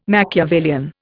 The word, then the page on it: machiavellian: Indian